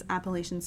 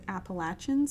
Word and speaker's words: Appalachian: That is how you pronounce it. – There are some people who might say that you pronounce it Appalachian Spring. – No. – I feel like that's a regional thing. – You're backing me up. I grew up at the base of the Blue Ridge Mountains in Appalachia, and you're pronouncing it correctly.